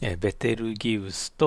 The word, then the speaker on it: ベテルギウス: ベテルギウスとリゲル。ベテルギウスはね、英語ではビートルジュースっていうふうに発音しますけれども、日本語ではラテン語由来のベテルギウスという呼び方をします。